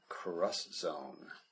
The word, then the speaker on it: Cross Zone: And Namco Bandai did have a very awesome game though, and that was Project Cross Zone, or not X Zone, as most people might think it would be called. For some reason, in Japanese, X is pronounced cross.